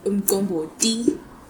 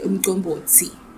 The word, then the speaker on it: Umgombothi: In South Africa, it will be called Umgombothi. But in Swaziland, this is the proper way to say it, umgombothi.